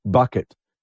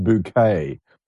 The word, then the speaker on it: Bucket: And the lead character was Hyacinth Bucket, and she always said it's pronounced bouquet, not bucket.